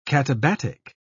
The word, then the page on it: katabatic: British English